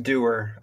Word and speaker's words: Dewar: It's pronounced Dewar. I believe originally there was a umlaut over the U, but Ellison Island and all that, and that disappeared.